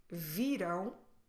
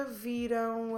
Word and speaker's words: viram: When you say viram, make sure you stress the second to last syllable, vi, but then close your lips in the end to make the nasal um.